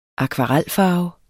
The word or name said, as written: akvarelfarve